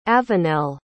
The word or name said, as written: avenelle